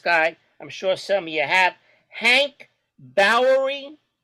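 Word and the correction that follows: Borowy: Barrowy.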